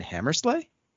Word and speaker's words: Hammersla: Until next time, my name is Jason Hammersla. I hope I'm pronouncing that right the Inquisition.